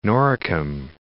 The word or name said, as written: Noricum